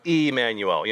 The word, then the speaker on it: Immanuel: I think they spelled it with the E because it would be more appropriately pronounced Immanuel. You know, in a lot of different languages, that I is pronounced as an E sound. So, same pronunciation, just a different spelling.